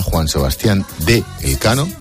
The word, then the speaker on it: Juan Sebastián de Elcano: Mira, hablando de Fuerzas Armadas, 18 años después de su última visita, el buque escuela Juan Sebastián de Elcano, se dice así, nos lo han enseñado esta mañana, ¿verdad? – Es verdad, es verdad.